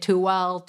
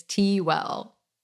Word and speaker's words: Tewell: Everyone has always butchered it. I get Tewell, but it's Tewell.